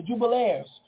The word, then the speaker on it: Jubilaires: Like the Jubilaries. Am I saying it right? The Jubilaires. The Jubilaires.